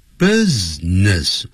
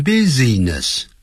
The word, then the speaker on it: business: business 别念成 business business 那个 B-U-S-I 那个 I 是不发音的 business 更不要念成 business 没有这样的发音